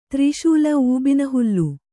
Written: ತ್ರಿಶೂಲ ಊಬಿನಹುಲ್ಲು

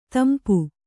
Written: ದುಂಪ

